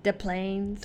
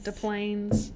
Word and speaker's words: Des Plaines: And just so you know, that's how you say it. It's not Des Plaines. – Des Plaines.